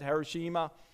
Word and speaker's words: Hiroshima: Hiroshima.